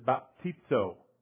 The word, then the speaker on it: baptizo: The word is baptizo.